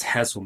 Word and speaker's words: Hesel: Like so, but the pronunciation of it is H E S E L with inverted E's, but it's Hassle Media.